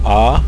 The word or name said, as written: ah